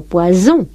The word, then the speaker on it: poison: Esto hay que tener cuidado con ello, porque podemos estar diciendo pescado, poisson, o veneno, poison. De modo que ustedes ven como hay que tener mucho cuidado en pronunciar bien esa S sorda o sonora.